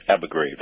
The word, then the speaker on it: Abu Ghraib: Abu Ghraib, there you go.